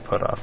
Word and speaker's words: Puthoff: It's got to be Puthoff, right? – I think the H is silent.